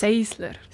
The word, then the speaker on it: Sensler: Seisler. – Seisler? – Seisler. – Wie schreibt man das? – Sensler. – Wie schreibt man das? – Sensler mit N. S-E-N-S-L-E-R. – Und man sagt Seisler? – Ja, anscheinend.